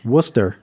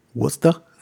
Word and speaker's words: Worcester: Worcester. – Or if you're from there, you call it Worcester. – Worcester.